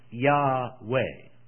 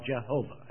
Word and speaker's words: Yahweh: J-H-V-H, and some say they have one vowel too many. It should be pronounced Yahweh. But in the Hebrew, it would be pronounced Yahovah, or Jehovah, as we know it.